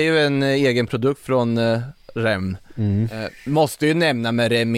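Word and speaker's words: Rennes: Men han, den unga killen från, vad säger man, Rem, det är så man uttalar då, ja.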